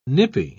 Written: nippy

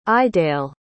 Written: Iwdael

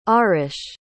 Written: Aarush

